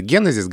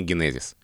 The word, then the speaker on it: генезис: строго и в официальной все-таки рекомендуется генезис говорить, но направление движения в сторону ударения генезис.